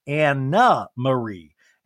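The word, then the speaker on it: Annemarie: And Andy's like, and let me clarify, it's Anna Marie. And Anne Marie goes, Anna Marie smelled like spelled like Anne Marie. It's a Dutch name.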